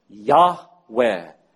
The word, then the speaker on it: Yahweh: And through other ways, we know that the second part of the name was pronounced Weh. Yahweh. That was most likely the way that the name of God was originally pronounced.